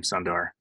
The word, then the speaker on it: Sundar: Oh yeah, speaking of YouTube, Sundar, I think you say his name, Sundar, the CEO. Right.